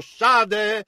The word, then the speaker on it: Shad: Ora qui troviamo, egli appare nel nome di El Shaddai, l'Onnipotente, il veramente la parola ebraica, Shad, S-H-A-D, alcuni la pronunciano Shade, Shad significa seno, come un seno di una donna.